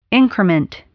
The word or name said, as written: increment